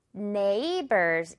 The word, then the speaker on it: neighbors: 그래서 neighbors 이렇게 갈 거죠. GH는 묵음처리.